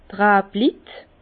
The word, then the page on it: d' Rablit: Bas-Rhin; Herrlisheim